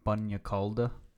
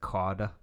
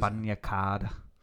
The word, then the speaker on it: bagna cauda: There's no L, but it's like the way you pronounce it, like cauda. – So what is bagna cauda?